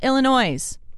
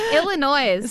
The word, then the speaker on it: Illinois: Thank you to Sarah in Colorado Springs, Greg in Manitou Springs, Diana in Williamette, Illinois. I'm so sorry. – Illinois.